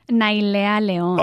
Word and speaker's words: Nailea Leon: You do it. Say it like the way. – Nailea Leon. – Ah, so much better.